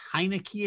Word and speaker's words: Heinicke: Can you say Taylor Heinicke at quarterback for Washington? – Nobody can say Taylor Heinicke. It's not spelled like Heinicke.